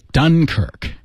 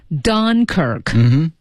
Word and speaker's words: Dunkirk: 怎么读？Dunkirk. – Dunkirk. D-U-N-K-I-R-K.